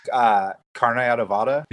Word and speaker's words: carne adobada: But carne adobada. – Yep, you're close. You said it basically without the Mexican accents.